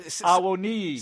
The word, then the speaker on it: Awoniyi: He scored, I guess, early in the second half for Nottingham Forest, and the assist was from a Nigerian player, Taiwo Awoniyi. I hope I pronounced that right. Awoniyi.